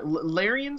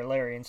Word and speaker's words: Larian: Larian Studios, is that it? – Yeah. Yeah, I got it here. Larian Studios.